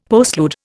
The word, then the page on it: postlude: Indian